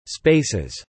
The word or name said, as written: spaces